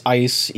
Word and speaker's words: Eis: My last name's first syllable is Ice, EIS.